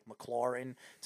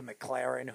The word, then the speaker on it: McLaurin: He makes fun of me by the way I pronounce people's names, like McLaurin to McLaren.